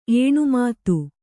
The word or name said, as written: ಏಣುಮಾತು